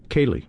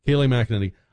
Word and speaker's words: Kayleigh McEnany: Is it Kayleigh McEnany? Am I saying that right, Don? – Kayleigh, yes. – Kayleigh McEnany.